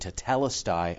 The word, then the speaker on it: tetelestai: Tetelestai. Well, yeah, tetelestai. The debt is paid. Yeah, you just said it right there.